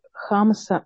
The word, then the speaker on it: хамса: И тут пришли небольшие поправки в чат о том, что ударение в Израиле ставится в слове «хамса» на первый слог, а «хамса» — это рыба.